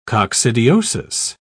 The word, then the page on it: coccidiosis: British English